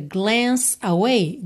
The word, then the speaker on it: glance: Percebe como a gente liga glance away, glance away.